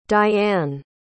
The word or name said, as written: dianne